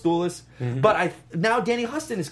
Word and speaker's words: Huston: Danny Huston. – Not Houston? – No, it's Huston.